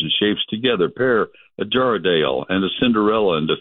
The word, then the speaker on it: Jarrahdale: Jarrahdale, I looked that one up and checked the pronunciation on it. J-A-R-R-A-H-D-A-L-E, Jarrahdale pumpkins are a beautiful steel blue-gray color.